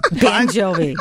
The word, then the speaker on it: Bon Jovi: How do you say his name again? Bon Jovi? – Bon Jovi. – Bon Jovi. – I was thinking of banjo.